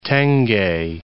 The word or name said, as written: tenge